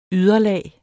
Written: yderlag

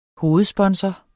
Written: hovedsponsor

